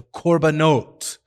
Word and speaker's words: korban: He vayikras him and communicates to him the laws of what's known as the korbanot. That's the plural of the Hebrew word korban. You'd spell that K-O-R-B-A-N, korban.